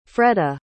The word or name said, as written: fredda